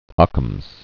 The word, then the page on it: Ockham's razor: Ock·ham's razor